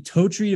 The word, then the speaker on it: Totri: I've got Anthony Totri.